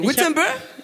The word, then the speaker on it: Wittenberg: I hope next time we come, I come, I'll be able to visit, is it Wittenberg, that's how we pronounce it? That's where he nailed the 95 Theses, right? – Ja. – Yeah, Wittenberg? – Yes.